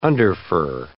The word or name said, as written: underfur